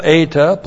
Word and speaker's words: eta: Eta. Long E, one letter.